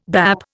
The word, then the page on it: bap: Indian